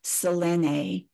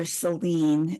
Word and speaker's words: Selene: So the moon, there are some differences between the Babylonian moon god that was a masculine or male divinity and the Greek goddess Selene, or Selene as it's pronounced.